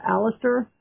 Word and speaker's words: Aleister: Aleister Crowley rejected Christian values, drank blood during ceremonies, and practiced sensual magic.